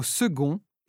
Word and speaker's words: second: Exceptionnellement, la lettre C peut se prononcer g, comme dans le mot second et tous ses dérivés.